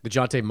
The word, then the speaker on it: Dejounte: The problem is, like, DeJounte, is that how you say it? – DeJounte Murray.